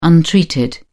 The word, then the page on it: untreated: British English